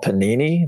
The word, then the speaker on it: Panini: But the old brands, like the new brand is, I think I'm pronouncing it right, it's called Panini. That's a real popular brand. P-A-N-I-N-I.